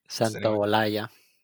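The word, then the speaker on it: Santaolalla: So Gustavo uh, Santa Loaya, I don't know if I said that right. Santa Loaya? – Santa Olaya. – Santa Olaya, okay.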